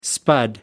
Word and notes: spud: American English

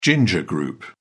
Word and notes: ginger group: British English

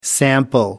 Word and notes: sample: American English

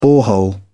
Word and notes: borehole: British English

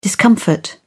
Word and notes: discomfort: British English

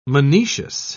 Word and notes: monoecious: British English

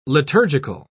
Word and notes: liturgical: British English

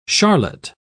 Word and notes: charlotte: British English

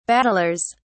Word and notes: battlers: British English

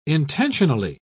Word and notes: intentionally: British English

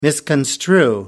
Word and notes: misconstrue: American English

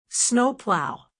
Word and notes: snowplow: American English

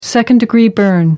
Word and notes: second-degree burn: American English

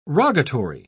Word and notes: rogatory: British English

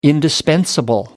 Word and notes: indispensable: American English

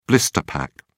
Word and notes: blister pack: British English

